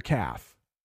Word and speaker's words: calf: So the lower part of your leg, behind it, is called your calf. But also a baby cow is called a calf. and you don't even pronounce that L.